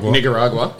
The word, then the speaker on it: Nicaragua: I saw an island for sale in Nicaragua. Is that how you say it? – Nicaragua.